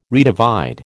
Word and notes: redivide: American English

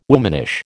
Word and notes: womanish: American English

